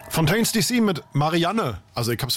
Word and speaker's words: Fontaines DC: Und du hast es wirklich falsch ausgesprochen, weil es ist Fontaines DC mit Marianne.